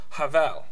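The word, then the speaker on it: Havel (הבל): In Hebrew, Abel is pronounced Havel.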